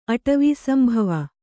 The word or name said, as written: ಅಟವೀಸಂಭವ